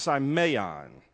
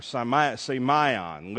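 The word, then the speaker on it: simeon (σημεῖον): The word sign is a little Greek word pronounced simeon, or simeon, literally is the way it's pronounced.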